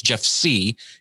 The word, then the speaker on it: Sieh: It's Jeff Sieh. I know it looks like Sia or Sia, but hey, everybody does that.